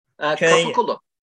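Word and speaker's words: Kapıkulu: I don't know if you said this correctly, but the Capiculi. – Capiculo. – Yeah. Yes, that's correct.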